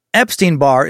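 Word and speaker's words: Epstein-Barr: Epstein-Barré is the virus that causes mononucleosis, mono, which is pronounced more like Epstein, like Jeffrey Epstein, and bar, like the exam to become a lawyer.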